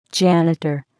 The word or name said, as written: janitor